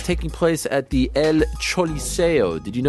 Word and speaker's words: Choliseo: Not the El Coliseo. It's the Choliseo. – Yeah, the Choliseo. – Even though it's pronounced C-O, it's actually, or it's spelled C-O, it's actually pronounced C-H-O in a roundabout way.